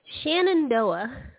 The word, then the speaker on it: Shenandoah: Tell me, how do you say Shandana Valley? How do you say that? – Oh, Shenandoah. Shenandoah Valley.